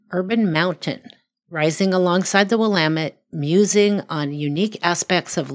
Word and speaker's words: Willamette: And because I live in Portland, I actually know how to pronounce Willamette.